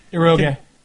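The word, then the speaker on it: Eroge (エロゲ): Sadly, Rape Lay is just one of a myriad of erogés containing, God, fucking Japanese word, I don't know how to say it. – Eroge. – Eroge? – Yeah. – Yeah, you would know.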